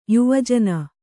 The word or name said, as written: ಯುವಜನ